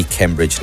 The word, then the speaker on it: Cambridge: Ni Cambridge Naranjo.